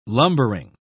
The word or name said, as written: lumbering